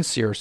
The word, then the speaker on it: SERE: If you have a helicopter as your choice, well, you're going to go to Search and Rescue School first, then Sears School, which is spelled S E R E, and it's an acronym.